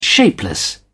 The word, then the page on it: shapeless: British English